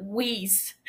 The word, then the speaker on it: wheeze: And Mary begins to whiz. Wheeze, whiz. No, that's not how you pronounce it. Wheeze.